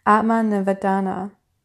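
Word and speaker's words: Atma Nivedana: The last principle of bhakti is Atma Nivada. Oops, I just said that wrong. My apologies. Atma Nivadana, which is self-surrender.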